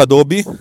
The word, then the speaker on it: Adobe: Si pronuncia Adobe, eh, ci ho messo un sacco di tempo anch'io,